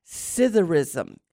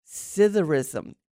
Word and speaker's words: psithurism: Scytherism. Scytherism. And it's spelled P-S-I. T-H-U-R-I-S-M.